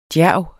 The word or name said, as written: djærv